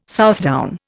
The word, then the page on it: Southdown: Indian